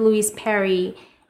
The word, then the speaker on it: Louise Perry: Um, I think Louis, I think it was a conversation you had with Louise Perry.